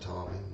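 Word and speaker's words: Tomi: I think it's pronounced Tommy, like a boy.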